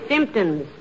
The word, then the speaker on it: symptoms: Got all the Simpsons. – The word is symptoms, dearie, with the emphasis on the first syllable.